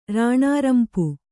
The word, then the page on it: ರಾಣಾರಂಪು: rāṇārampu